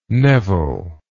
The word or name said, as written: neville